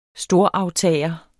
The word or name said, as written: storaftager